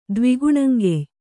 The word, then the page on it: ದ್ವಿಗುಣಂಗೆಯ್: Kannada